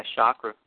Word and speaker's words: chakra: Am I pronouncing that correctly? – Yeah, chakra.